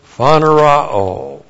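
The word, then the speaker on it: phaneroō: And then we have the verb phanerao. Phanerao, phanero, long o, phanerao.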